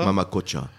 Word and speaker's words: Mama Kocha: il faut prononcer comme cela, hein, faut avaler le L, c'est bien ça ? – Mama Kocha.